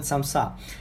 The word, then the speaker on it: samsa: Sometimes it's pronounced like samosa, but usually we here in Uzbekistan call it samsa.